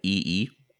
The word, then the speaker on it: Yi Yi: And I know one of the movies, it's a pretty famous one called EE. It's spelled Y-I-Y-I.